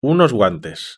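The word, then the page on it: unos guantes: European Spanish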